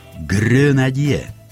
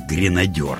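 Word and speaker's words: grenadier: Но французы и в самом деле произносят это слово через «ё». Но не в конце слова, а в начале. «Гренадье», но никак не «гренадёр» ведь.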